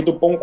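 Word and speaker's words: Dupond: No Brasil eles vieram com o original que foi lá de fora, do original mesmo, que é Dupont e Dupont. – É Dupont com T e Dupont com D, mas você não pronuncia a letra final, então...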